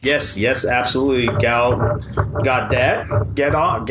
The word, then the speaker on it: Gadot: Which every time I saw it prior to hearing it pronounced correctly, I thought was Godot. – Godot. – Like, I just assumed it was a French pronunciation, but it's not.